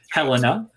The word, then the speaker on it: Helena: Kelly plays Helena? Is that how you say it? – Right. – Helena.